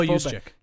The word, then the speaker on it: Juszczyk: The one thing they don't come out of here unscathed, and it's weird to say it, but big loss here is Juszczyk Am I saying that right? Just check. – Kyle Juszczyk.